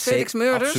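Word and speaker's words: Felix Meurders: Zeg ik het goed? Met Felix Murders? – Absoluut, ja.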